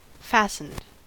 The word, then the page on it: fastened: American English